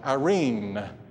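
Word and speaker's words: irene (εἰρήνη): That word peace in the Greek language of the Bible is pronounced this way, irene.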